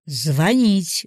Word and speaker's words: звонить: Звонить, ударение на «и», звонишь.